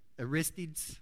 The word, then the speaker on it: Aristides: On one of these occasions, a famous Grecian philosopher from Athens named Aristides, who had been, say it again, Aristides, is that how you, Aristides, that's how you, okay, thank you.